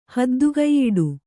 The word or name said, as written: ಹದ್ದುಗೈಯಿಡು